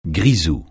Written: grisou

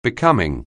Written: becoming

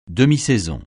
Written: demi-saison

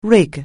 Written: rig

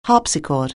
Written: harpsichord

